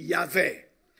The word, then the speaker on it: Javé: E o título da minha mensagem é Quem é como Javé? Quem é como Javé? Ou oh, Yavé, quem quiser dar assim uma pronúncia mais erudita.